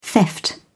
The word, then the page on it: theft: British English